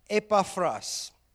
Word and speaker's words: Epaphras: And interestingly, there was a guy, let me just pronounce it right, Epaphras, in Paul's travels, his third missionary travel, scholars tell us, he went to Ephesus.